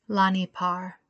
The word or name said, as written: Lahni Par